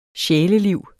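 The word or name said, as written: SJÆLELIV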